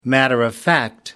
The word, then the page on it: matter-of-fact: American English